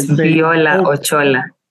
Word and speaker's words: Viola Ochola: Today, we on the human rights on the Africa Rights Talk, we are having a conversation today with Mrs. Viola Ochola. I hope I pronounced that correctly. – Yes, Viola Ochola.